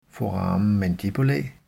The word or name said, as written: foramen mandibulae